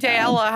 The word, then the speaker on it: Daella: None of those, but you can say Daella how you want. That's how I say it. It's just, it rhymes with Paella.